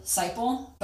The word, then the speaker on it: Seipel: Seipel. But it looks like Seipel.